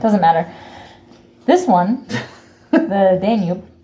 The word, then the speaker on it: Danube: Several people were rumored to have thrown themselves into the Danube. That's the cat. – Yeah, I can see her. The Danube? – Danube? Is that how it's said? – Mm-hmm.